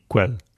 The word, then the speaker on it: Quelle: Q-U-E. LLE, which you, since you speak German, might that's Quelle.